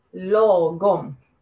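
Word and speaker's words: lagom: Se escribe la, pero se dice Logom.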